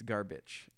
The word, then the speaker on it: Garbitsch: It's Garbage. – Garbage. – Yeah, it's spelled Garbitsch.